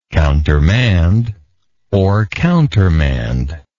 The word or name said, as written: countermandable